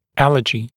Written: allergy

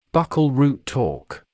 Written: buccal root torque

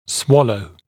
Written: swallow

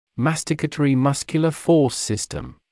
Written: masticatory muscular force system

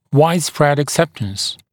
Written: widespread acceptance

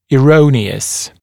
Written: erroneous

